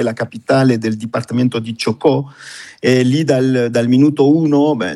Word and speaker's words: Chocó: So che siete impegnatissimi, un team di circa 24 persone, se non sbaglio, opera nel dipartimento di Chocó, se si pronuncia così. Ci aiuti lei, come si pronuncia e dove si trova questo dipartimento, che è stato l'epicentro un po' delle varie scosse di terremoto degli ultimi giorni? – Sì, esattamente. Allora